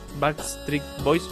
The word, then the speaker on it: Backstreet Boys: O sea, vos tenés eh, un recuerdo de tu infancia con un cassette de Backstreet, Backstreet Boys, ¿se pronuncia bien así? – exactamente, en un viaje.